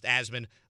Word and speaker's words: Asman: It's pronounced Asman like Jasmine.